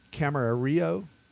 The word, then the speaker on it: Camarillo: So the four locations starting April 28th and 29th, Camarillo.